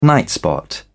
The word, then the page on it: nightspot: British English